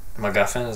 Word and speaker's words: MacGuffin: I still didn't know if the lights were supposed to be bad or good, or they were just MacGuffin. Is that what the word is? Am I saying that right? – Yes.